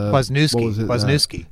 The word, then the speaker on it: Kwasniewski: Kwasniewski.